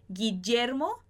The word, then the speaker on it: Guillermo: Aqui aquele som do G, né? Como se fosse em gato. Gui. Guillermo, igual em português. Gui de Guilherme, gui de Guillermo.